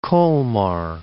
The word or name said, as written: Kolmar